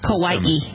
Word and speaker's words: Kauai: It's Kauai. – Kauai? – Kauai. – Like Hawaii with a K. – K-A-U-A-I is Kauai? Kauai. – What? – Really? – Not Kauai.